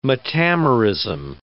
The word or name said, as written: metamerism